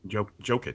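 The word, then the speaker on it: Jokić: Jokic.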